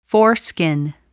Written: foreskin

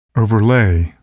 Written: overlay

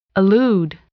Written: allude

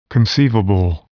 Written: conceivable

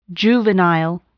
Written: juvenile

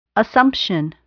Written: assumption

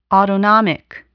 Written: autonomic

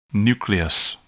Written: nucleus

